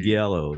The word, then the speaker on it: Giallo: I would say get, like, what do you mean Yalo? You're not talking about Giallo. – Yeah, you guys are actual Guineas. I don't know, I don't speak your funny talk. But I pronounce it how it's spelled. Giallo. Yeah, Giallo, right.